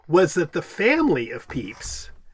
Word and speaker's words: Pepys: His last name is spelled P-E-P-Y-S, and I'm pretty sure that is pronounced Peeps, like the little marshmallow things. – You got it, Fletcher. It is Peeps.